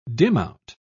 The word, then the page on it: dimout: British English